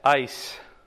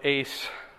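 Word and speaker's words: eis: It's E-I-S, ice, or ace, depending on how you pronounce it.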